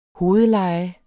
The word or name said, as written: hovedleje